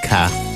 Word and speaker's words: 卡: There's no RD pronunciation. It's just ka.